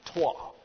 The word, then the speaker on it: trois: We throw those in, but here's how you say it. Trois.